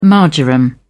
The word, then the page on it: marjoram: British English